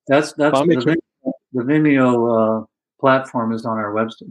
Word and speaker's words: Vimeo: I watched it on, if I'm pronouncing it right, Vimeo.